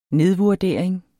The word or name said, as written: nedvurdering